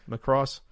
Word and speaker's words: Macross: Macross.